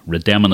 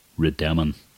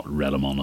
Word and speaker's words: Rademon: how to say that name, Rademon. Not Rademon, as I incorrectly pronounced it.